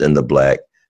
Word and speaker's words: NTHBLK: I want to make sure you know that it's pronounced In the Black, but it's spelled NTHBLK.